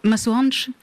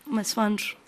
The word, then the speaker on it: Masoandro: Masoandro? – Masoandro. – C'est vrai qu'on ne prononce pas la fin, la dernière syllabe.